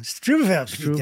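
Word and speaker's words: Struwwelpeter: The W's are V's. – Oh, Struwelpeter. Struwel.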